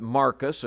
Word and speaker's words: Marcus: But Marcus, of course, is the Greek pronunciation of that name.